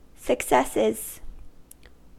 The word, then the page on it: successes: American English